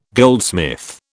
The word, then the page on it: goldsmith: British English